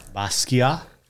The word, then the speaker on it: Basquiat: Basquiat. – Basquiat.